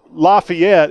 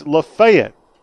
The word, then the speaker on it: Lafayette: It's not La, Lafayette, it's Lafayette, Georgia. Yeah, I'm very familiar with that one.